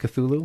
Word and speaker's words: Cthulhu: It's spelled C-T-H-U-L-H-U. We're pretty sure it's pronounced Cthulhu. – Cthulhu. – Cthulhu, okay.